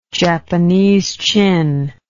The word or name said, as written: Japanese chin